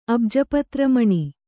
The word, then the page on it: ಅಬ್ಜಪತ್ರಮಣಿ: kannada